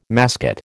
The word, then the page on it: mascot: American English